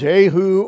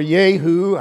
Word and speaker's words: Jehu: it's variously called Jehu or Yehu, I prefer the Y pronunciation, and Jehonadab.